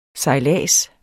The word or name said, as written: sejlads